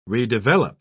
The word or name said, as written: redevelop